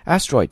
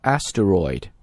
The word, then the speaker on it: asteroid: 一个房子大小的 asteroid, asteroid 这个单词的意思呢就表示叫小行星。大家注意这个单词的读法啊，我们既可以把它读成 asteroid, 我们也可以把它读成 asteroid。其中呢在这里 aster 那个 ter 可以把中间那个 e 缩掉，直接读 tr 这个音。